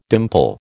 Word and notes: dimple: American English

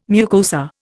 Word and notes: mucosa: Indian

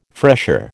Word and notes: fresher: American English

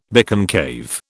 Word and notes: biconcave: British English

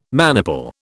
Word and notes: maniple: British English

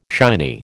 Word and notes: shiny: American English

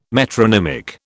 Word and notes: metronymic: British English

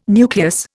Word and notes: nucleus: Indian

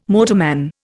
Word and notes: motorman: Indian

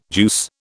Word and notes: juice: British English

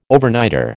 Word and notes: Overnighter: American English

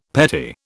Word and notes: petty: British English

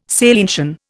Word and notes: salientian: Indian

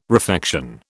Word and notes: refection: British English

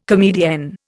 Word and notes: comedienne: Indian

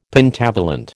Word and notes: Pentavalent: American English

